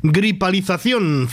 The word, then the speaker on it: gripalización: Es una palabra de esas que el año que viene, cuando se habla de palabras que la Real Academia ha aceptado, aparece gripalización, cinco sílabas, como felicitación.